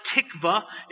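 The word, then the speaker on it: tikvah: It's the word, it's pronounced tikva.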